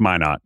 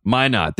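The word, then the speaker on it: Minott: And you can correct me if I pronounce this wrong, because I always do. But Josh Minot, right? – I think it's Minot. – Minot. There you go.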